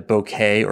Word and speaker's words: bokeh: We term that blurring effect a bokeh or bokeh, tomato, tomato, in terms of how you pronounce that, B-O-K-E-H.